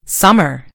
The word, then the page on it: summer: British English